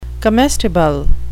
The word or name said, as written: comestible